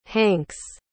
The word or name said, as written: hanks